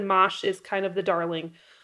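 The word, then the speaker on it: mâche: And for those of you who are new to this, it's M-A-C-H-E. And I actually had to Google how to pronounce it because I kept calling it mache. People sometimes I only had read it. I had never heard someone talk about it. So I was like, I'm planting mache. And then I Googled it and I'm like, oh, it's pronounced mosh.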